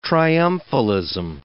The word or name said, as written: triumphalism